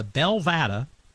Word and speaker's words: Belvada: The Belvada has opened. Am I saying that right? Belvada, or is it Belvada? – Yes, you are. The Belvada Hotel.